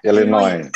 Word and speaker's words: Illinois: Amerika Serikat ini bacanya gimana ya Bu ya Universitas Illinois ya? – Illinois.